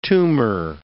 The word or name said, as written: tumor